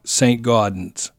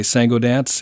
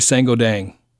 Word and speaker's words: Saint-Gaudens: It's right downtown in Saint-Gaudens, and that would be, if you were reading it with an English pronunciation, you would think Saint-Gaudens, but we say Saint-Gaudens in France, and the locals say Saint-Gaudens.